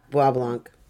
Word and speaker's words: Bois Blanc: And since Mackinac and, I forgot to get the pronunciation for this one, Bois Blanc Islands? I'm going to go with that one. We're somewhat isolated. They became... – How's it spelled? – B-O-I-S. – Yeah, Bois Blanc. – Bois Blanc, yeah.